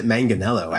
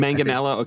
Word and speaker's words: Manganiello: I think he says it Manganiello, actually. – Manganiello. Okay, yeah.